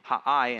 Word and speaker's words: Ai: Joshua, after the defeat of Ai, after his defeat of Ai, it's spelled Ai, but it's really Ha'ai in Hebrew, Ha'ai, but after the defeat, what happens?